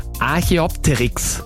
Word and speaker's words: Archaeopteryx: Ich würde jetzt nochmal den Arche, den muss ich mir mal konzentrieren bei der Aussprache, Archaeopteryx.